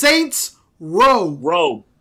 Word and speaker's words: Saints Row: It's Saints Row. – Row. I know. It's a row. It's not Rose.